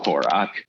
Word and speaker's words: Horak: I spell it like Ack or Ick, but it's o- pronounce it O C K. So Horak.